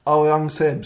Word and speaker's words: Aurangzeb: Aurangzeb, tror jag. – Aurangzeb, ja.